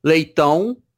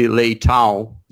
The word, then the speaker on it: Leitão: So everybody calls me Leitão, but actually, if you're Brazilian, you call my name Leitão, and for an English speaker, probably the most reasonable would be Leitão. Closer you get.